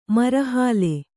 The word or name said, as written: ಮರಹಾಲೆ